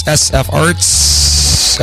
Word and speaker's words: sfarts: Again, that's sfarts, emphasis on the S.